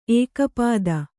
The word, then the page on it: ಏಕಪದಿ: kannada